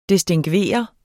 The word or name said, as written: distingveret